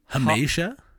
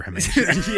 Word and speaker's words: hamartia: Hamartia. – Hamartia. hamartia. – Is that confirmed or how you're interpreting? – That's how Google told me to pronounce it.